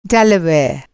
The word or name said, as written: डेलावेयर